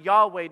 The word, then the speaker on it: Yahweh: capital L-O-R-D, all capital, which means Yahweh, or the unwritable name. It's pronounced Yahweh to show himself as more than creator, but personal.